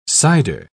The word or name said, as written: cider